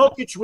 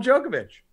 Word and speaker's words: Jokic: Jokic was the one that fucked me. They just you got said his name right. – What? – You didn't call him Djokovic.